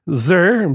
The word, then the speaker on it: Fraser: Zer, because that's how you pronounce it.